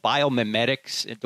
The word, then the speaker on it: biomimetics: Is this called biomimetics? Do I have that pronounce, pronunciation right, Fuzz? yes, you do.